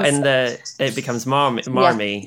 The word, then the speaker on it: Marmee: Um, and I had to Google that, and and what I learnt was in the in the original story they would have pronounced the R as an H, so it would have been like "mommy."